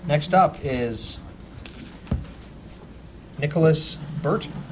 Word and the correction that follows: Bua: It's Bua. – Bua. – Nicholas Bua. – Yeah, it's a short Sicilian name.